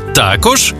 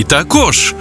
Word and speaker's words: також: 5 українських слів із подвійним наголосом. Байдуже, байдуже, завжди та завжди, мабуть та мабуть, також і також, помилка і помилка.